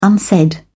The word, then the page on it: unsaid: British English